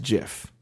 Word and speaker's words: GIF: No, because the guy who created the actual GIF computer thing or whatever, I don't know what it's called, software or whatnot, he says it's pronounced Jif, right? – Oh, okay. So but technically, then it would be pronounced. – No, it's not. And I'll tell you why. Because GIF is an acronym for Graphics Interchange Format. Graphics Interchange Format. So it's GIF.